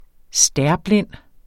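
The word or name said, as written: STÆRBLIND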